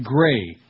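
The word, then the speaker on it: Grey: Gray. Rhymes with gay.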